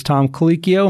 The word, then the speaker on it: Colicchio: The opening chef was Tom Colicchio. Am I saying that right? – That's right.